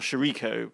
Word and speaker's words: Chirico: Yes, it is Louisa Chirico, who is, or Chirico, let's give it the American pronunciation, who is the wild card, and she's a 19-year-old.